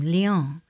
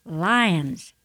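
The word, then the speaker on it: Lyons: Halfway through Georgia, we entered a town and pronounced its name, Lyon. But the radio DJ corrected us a moment later, Lions, making me think of open grasslands and peril.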